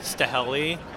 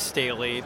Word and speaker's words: Staheli: People usually pronounce it Staheli, but it's Staley, so thank you.